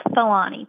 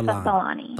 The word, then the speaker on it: Tholany: It's pronounced Tholany, Teresa Tholany.